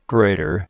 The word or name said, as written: grater